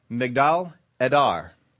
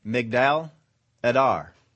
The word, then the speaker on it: Migdal Edar: In the Hebrew, it was, and my Hebrew is not all that good, but uh, it is Migdal Edar. I will spell that for you because you may want to go home and Google this. M-I-G-D-A-L, and then the word Edar, E-D-A-R. Migdal Edar.